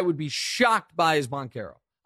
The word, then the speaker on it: Banchero: I'm going to give you a 9 out of 10 because you mispronounced Banchero's last name, but you did it in a way a lot of people do. You did the ch and it's k. So Banchero.